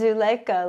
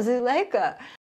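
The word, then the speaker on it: Zuleikha: When I go into schools, I would say, hi, my name is Zuleika, like a zoo and a lake and And then they would go, oh, Zuleika. It's spelled Z for zebra, U-L-E-I-K-H-A.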